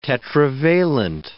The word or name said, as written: tetravalent